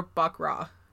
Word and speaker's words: baccarat: Is it baccarat or baccarat? Do you know? – I think it's baccarat. I think you pronounce all the letters.